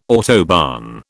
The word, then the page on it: autobahn: British English